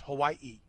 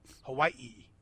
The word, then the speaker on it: Hawaii: Like, every vowel is pronounced. He said, so it's Hawaii. Hawaii. It's like you got to kind of drag the I's. Mm-hmm. Both I's get pronounced. Right? It's I apostrophe I.